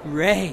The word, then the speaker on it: Rhea: That's Ray, but Ray. – Great first time. – R-H-E-A, pronounced Ray.